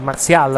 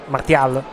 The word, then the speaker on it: Martial: Vi posso confermare che l'assist da parte per il gol 2-0 è stato di Anthony Martial, anzi Martial per la precisione, e non Martial, se vogliamo dare la pronuncia, una pronuncia simile a quella corretta.